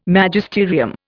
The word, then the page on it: Magisterium: Indian